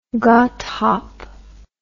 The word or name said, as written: godthaab